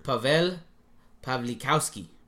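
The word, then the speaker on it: Paweł Pawlikowski: Pawlikowski. All right, let me try this again. – Good. – Pavel Pawlikowski. That's a good pronunciation.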